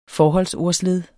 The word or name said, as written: forholdsordsled